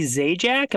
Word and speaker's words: Zajac: I'm here with Kimberly Zajac.